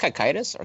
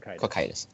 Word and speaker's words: Cocytus: So is it pronounced Cocytus or Cactus? – Cocytus. – I want to be straight then. – Cocytus.